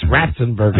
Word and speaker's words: Ratzenberger: It's Ratzenberger.